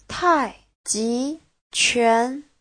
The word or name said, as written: tai4ji2quan2